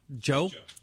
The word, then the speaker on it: Djo: And, the song before, because I didn't tell you what it was, is Personalized by, I never knew how to pronounce it. Joe, spelled D-J-O.